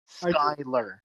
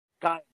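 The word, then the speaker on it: Schuylear: It's S C H U Y L E A R. Skylar.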